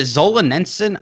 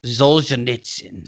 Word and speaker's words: Solzhenitsyn: Is it Zolzhenitsyn? I don't know how it's said. Alexander Solzhenitsyn. – Solzhenitsyn.